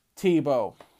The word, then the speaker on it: Tebow: And it is all spearheaded by former NFL player and college football great Tim Tebow.